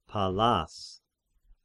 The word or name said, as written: Pallas